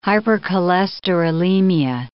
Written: hypercholesterolemia

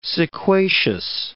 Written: sequacious

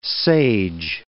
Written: sage

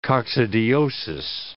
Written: coccidiosis

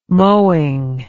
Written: mowing

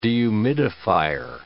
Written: dehumidifier

